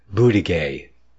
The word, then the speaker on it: Buttigieg: Is a Department of Transportation czar, Pete Buttigieg. That's right. I think the G is silent at the end, isn't it? At least that's what David Knight says. Yeah, the G at the end is silent.